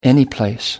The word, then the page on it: anyplace: American English